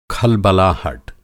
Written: खलबलाहट

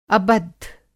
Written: अबद्ध

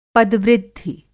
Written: पदवृद्धि

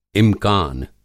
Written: इम्कान

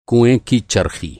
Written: चरख़ी

